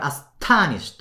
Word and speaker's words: astonished: ここに astonished とちょっと強めにアクセントを入れてください。